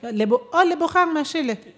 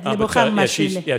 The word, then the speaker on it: Lebogang Mashile: Lebogang Mashile. – Yeah. – Sorry. – Yeah, no. I was just checking my pronunciation there. – Yes, Lebogang Mashile.